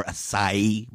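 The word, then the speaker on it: açaí: You know, we've all seen a mouth-watering healthy smoothie or acai bowl. I can never say that word right. You said it. I think you said it right.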